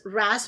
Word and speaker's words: wrasse: That's right, fish like gobies and the cleaner wrasse, yes, wrasse rhymes with sass, which I've got plenty of.